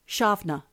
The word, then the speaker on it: Samhain: It is the season of, it's spelt Samhain, but it's actually pronounced in Celtic Irish, Shavna.